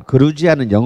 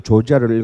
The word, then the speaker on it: 그루지아: 그러니까 이미 이제 3,500년 경이 되면 어, 여기에서 시작했던 와인이 아까 그 그루지아 그루지아는 영어로는 조지아예요. G O G E O G I A 그걸 조지아를 읽으면 촌놈이고요. 그거는 이제 트럼프나 조지아를 읽는 거고 어, 우리는 그루지아라고 읽어야 됩니다.